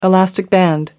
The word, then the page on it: elastic band: American English